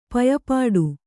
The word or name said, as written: ಪಯಪಾಡು